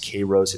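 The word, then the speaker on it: Kayrros: A lot of people commenting on it about the validity of the results that the analytics firm, I think it's pronounced Kairos. It's K-A-Y-R-R-O-S.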